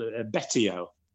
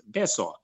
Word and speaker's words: Betio: So if you see that, you know, it's pronounced S. And in a Western mind, that really does not compute. It's so difficult to get that into your head. And the biggest city, it's called, it looks like it's called Betio. B-E-T-I-O, but of course that's pronounced Beso.